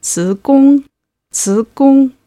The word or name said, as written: zigong